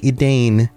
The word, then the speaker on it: Edain: Even though Edain is spelled E-D-A-I-N, looks like Edain. Edain is the more accurate pronunciation.